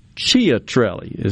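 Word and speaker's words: Ciattarelli: And Ciatrelli is the way to pronounce it, I believe, C-I-A, is leading with 5,100 votes doing the math in my head here.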